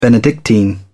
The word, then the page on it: benedictine: British English